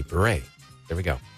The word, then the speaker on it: Bure: How about Candace Cameron Bure? – Yeah, that was guessed. – Bure. Okay, so that's how you pronounce it.